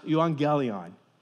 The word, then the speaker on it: euangelion: It's pronounced euangelion.